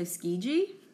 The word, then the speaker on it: Tsukiji: I think it's, I think the T is silent, so Tsukiji. – Tsukiji Fish Market.